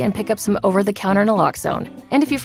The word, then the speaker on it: naloxone: Naloxone reverses an opioid overdose, even if you mispronounce it. For the record, it's Naloxone. Nah, like, is Naloxone addictive? Nuh-uh. Locks like Naloxone keeps humans safe like locks keep bikes safe, and own like Naloxone can save lives in our very own communities.